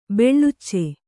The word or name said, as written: ಬಿಳಿಚ